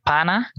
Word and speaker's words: pana: Something like that, which in Venezuela is like pana. – Okay. – Pana. Oh, yeah, pana comes pana. From partner, the word partner, but pronounced like in British English, parna.